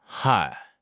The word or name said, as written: 嗨